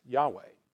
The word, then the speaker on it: Yahweh: It was YHWH, which we pronounce Yahweh.